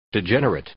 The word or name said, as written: degenerate